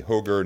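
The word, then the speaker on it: HOGR: It used to be Hoger, now it's Hork. Hoger rhymes with ogre.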